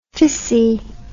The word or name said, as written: fissi